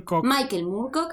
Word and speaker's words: Michael Moorcock: Entre sus inspiraciones, él nombraba a Michael Moorcock, no sé si lo dije bien, y Úrsula... – Michael Moorcock, ahí está, y Úrsula Le Guin.